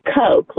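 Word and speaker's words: Koch: It's Coke, like Coca-Cola. – No. – Gross. – Like cocaine. Right. Oh, boy. – And it's spelled C-O-K-E? – It's K-O-C-H.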